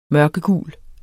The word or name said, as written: mørkegul